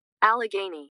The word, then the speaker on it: Allegheny: In 1880, Mary Jane moved her children that were still at home, which included Elizabeth, to, I don't know how to say this, Allegheny City? I think it's how you say it. Let's look it up. Here we go. – Allegheny. – Allegheny City.